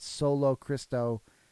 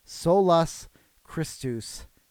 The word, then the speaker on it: Solus Christus: Now, this is one where some people put solo Christo. That's not correct. Or try and fit a sola Christa. That's not right. Solus Christus is how you say it. It breaks the, I know it breaks the pattern of sola, sola. But this is it. But this is the proper Latin, people.